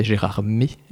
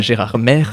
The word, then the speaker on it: Gérardmer: bien sûr bien prononcé Gérardmer, et pas, pas Gérardmer, comme ça.